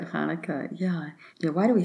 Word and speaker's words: Hanukkah: It's really Hanukkah.